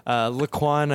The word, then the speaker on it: Laaouan: Well, when I was in camp in Arizona, a couple of the boys thought there was a Q in there. So my nickname kind of became Quaner when I was there. Laaouan, I think, might be up there for sure, considering there's no Q in the name.